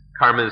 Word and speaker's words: karma: But if we, we understand karma, kamma, karma is the Sanskrit pronunciation, kamma, the Pali pronunciation.